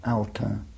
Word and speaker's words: Autun: A-U-T-U-N, Auta.